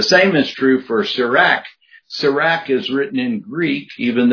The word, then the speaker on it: Sirach: You hear that even in the name, Sirach. We pronounce it with that ah sound instead of the ah that you would get from a Hebrew word, Sirach.